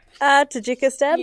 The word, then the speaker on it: Tajikistan: Tajikistan, yeah.